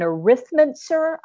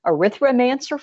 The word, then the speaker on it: arithmancer: I have some trouble saying that word because I want to put another syllable in there. I want to say arithromancer for some reason. So I have to to think consciously about that.